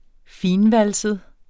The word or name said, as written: fintvalset